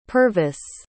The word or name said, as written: purvis